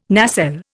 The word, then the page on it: nacelle: Indian